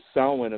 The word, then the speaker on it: Samhain: And if you try to spell it, it'll spell like it's Samhain, but that's Samhain, and that's where the roots of Halloween come from.